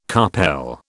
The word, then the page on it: carpel: British English